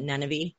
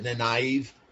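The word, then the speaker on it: Nynaeve: I pronounce it Nenevi. – Okay. – On the show, they pronounce it differently. – Yeah, on the show they pronounce it Nenive.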